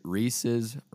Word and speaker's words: Reese's: You need to tweet and say Reese's rhymes with pieces, not Reese's Pieces.